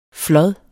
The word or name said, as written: flod